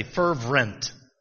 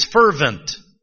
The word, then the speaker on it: fervent: And if the effectual, fervent, I always used to pronounce that word wrong. I'd say fervent. F-E-R-V-R-E-N, fervent, and it's not. It's fervent. The last half of the word is vent.